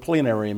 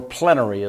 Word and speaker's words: plenary: First of all, what does the word plenary mean? Or plenary, as some people would pronounce it. P-L-E-N-A-R-Y.